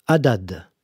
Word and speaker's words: Haddad: Haddad. H-A-D-D-A-D.